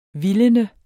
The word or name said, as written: vildene